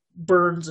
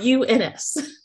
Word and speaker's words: Bruns: And to make sure I am pronouncing your last name right, is Bruns? – Yes, and for the record, you are one of few people who gets it right, so... – I may have said Burns a few times off mic.